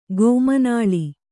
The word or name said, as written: ಗೋಮನಾಳಿ